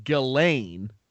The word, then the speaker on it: Ghislaine: Ghislaine, to your credit, this is a very weirdly spelt name, but it's Ghislaine. – No way.